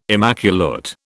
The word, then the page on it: immaculate: British English